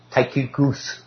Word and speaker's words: Tychicus: So who's this guy, Tychicus? That's correct. I keep apologizing for that. That's the way it's pronounced.